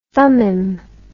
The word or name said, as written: thummim